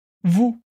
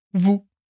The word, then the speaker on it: vous: E aqui eu aproveito para lembrar que tanto em nu quanto em vous, a gente tem um S no final da palavra. Mas, seguindo a regra geral de pronúncia que eu te expliquei na aula, esse S não é pronunciado. Por isso, a gente tem nu e vu.